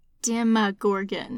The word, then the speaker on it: Demogorgon: It is pronounced Demogorgon.